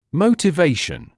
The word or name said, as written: motivation